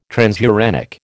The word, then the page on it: transuranic: American English